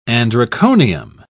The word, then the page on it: androconium: British English